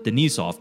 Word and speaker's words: Denisov: Edison Denisov, and apparently according to a Russian music scholar, that's how you pronounce it, Denisov, was exploring sound in the 1970s with multiphonics.